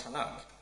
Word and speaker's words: Tanakh: which is why we get the name Tanakh, T-N-K, pronounced Tanakh.